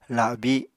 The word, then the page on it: labi: Apatani